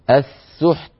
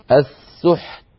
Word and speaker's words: السحت: فنأتي بالحاء ساكنة ثم نتبعها بتاء ساكنة، نأتي أولا بصفة الشدة فيها وهي انحباس الصوت ونتبع ذلك بالهمس، فيصير النطق بمجموعه هكذا السحت، فالحاء تأخذ حظها من السكون ولا تتأثر، والتاء تأخذ حظها من السكون بشدتها وهمسها، فلا يؤثر حرف على الآخر.